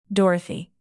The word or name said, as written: dorothy